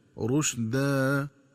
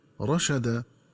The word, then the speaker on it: رشدا: وكذلك ينبغي التنبه للنطق الصحيح لكلمة رشدا، فتقرأ بضم الراء وتسكين الشين هكذا رشدا، ومن الخطأ قراءتها بفتح الراء والشين هكذا رشدا.